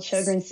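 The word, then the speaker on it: Sjögren's: So what you said, you had Sjogren's. Is that, am I pronouncing it correctly? – Yeah, you've got it right. It's called Sjogren's syndrome.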